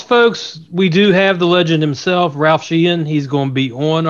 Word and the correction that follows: Sheheen: gotta learn how to pronounce his name properly. You said it wrong too. It's Ralph Sheheen.